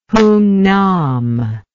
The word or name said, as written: hungnam